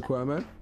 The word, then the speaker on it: Cuomo: Cuomo? – Killer Cuomo.